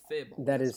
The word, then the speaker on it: Firbolg: Okay, you, Firebolg, keep us alive. That's your job. Just keep us alive. – Okay. – You're a cleric. – I believe it's pronounced Firbolg. – That is correct.